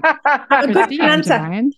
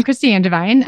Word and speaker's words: Kristi Angevine: I'm Kristi Angevine. You're a good pronouncer. So I'm Kristi Angevine.